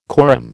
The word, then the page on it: corymb: American English